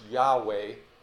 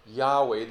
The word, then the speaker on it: Yahweh: The Hebrew is best pronounced Yahweh. Yahweh, the I am God, the living and true God.